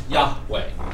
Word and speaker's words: Yahweh: And it, if you then sound it out, it's Yah, with the H at the end, Yahweh, or Yahweh, depending upon your preference of pronunciation. Usually pronounced Yahweh. Again, it's from right to left, Yahweh.